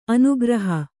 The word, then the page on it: ಅನುಗ್ರಹ: anugraha